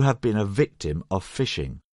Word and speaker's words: phishing: First of all, we should explain that even though the pronunciation is the same, this variety of phishing is spelt with a PH and not an F.